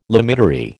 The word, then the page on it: limitary: American English